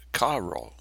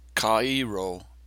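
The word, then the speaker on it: kairo (χαίρω): He uses the word rejoice, which in the Greek is kairo, pronounced kairo, but spelled C-H-A-I-R-O.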